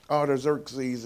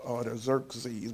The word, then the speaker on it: Artaxerxes: Artaxerxes is how I should have pronounced that, Artaxerxes.